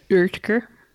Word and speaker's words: Oetker: So Dr. Oetker. – Uh-huh. – It's actually Dr. Oetker. – Really? – Yeah, because they showed it in the spelling. Ertker. Oetker. – Okay. Oetker. – Okay. Nike. It's actually Nike, N-A-I-K-E-Y.